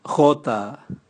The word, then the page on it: gota: Raga